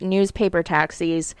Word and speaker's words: NWSPAPRTAXIS: The author's name, I think, is supposed to be pronounced Newspaper Taxis, but it's spelled very interestingly. It's NWSPAPRTAXIS.